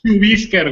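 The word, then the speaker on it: Chuvíscar: Chubisca. – Chubisca. – Es un nombre indígena. – Chubíscar, güey, con la she. – Chubíscar. – Chubíscar. – Acento en la I. – Perdón, C-H-U. – V. – V. – I. – I-S-C-A-R.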